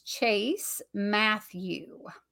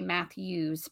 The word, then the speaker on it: Matthew: What's so cool is, and I'm sure people say it because it's Chase Matthew, and I think people want to say Matthews, but I'm like making sure that I say it properly.